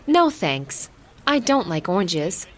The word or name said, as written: no thanks